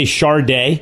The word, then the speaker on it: Sade: Go cover Remember when it turned out you had to say Sharday? I was like, what? Why does she spell it Sade if it's Sharday? Where's the R?